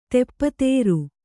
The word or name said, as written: ದಪ್ತರು